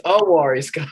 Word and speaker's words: Unwary Sky: You're putting the N in there. Everybody puts the N in there. It's Unwary Sky.